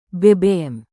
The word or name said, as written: bbm